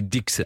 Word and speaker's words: Dix: Même si ça s'écrit, vous voyez, comme ça, on dit Dix.